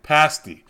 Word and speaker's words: pasty: Pasty. – It was close. – It's spelled like pasty, but it's not pronounced that way. They will yell at you if you say it that way.